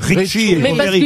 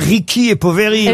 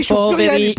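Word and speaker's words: Ricchi e Poveri: Julie me dit qu'il faut dire Ricky et Poveri. – Ouais, exactement. – Enfin, je suis désolé. On entendait les animateurs tout le temps dire Richie et Poveri.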